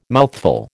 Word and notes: mouthful: American English

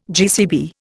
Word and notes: GCB: Indian

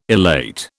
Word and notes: elate: British English

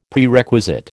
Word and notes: Prerequisite: American English

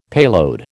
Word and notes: payload: American English

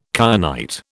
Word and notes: kyanite: British English

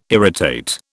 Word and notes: irritate: British English

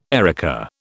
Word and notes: erica: British English

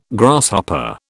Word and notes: Grasshopper: British English

